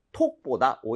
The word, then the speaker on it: talk: 오케이, 그래서 발음할 때 근데 talk라고 하지 말아주시고요. – 네. – A의 강세를 주시고 그리고 입모양을 좀 벌려서 talk보다 오히려 talk 이렇게 talk. – 어에 가깝습니다. 차라리.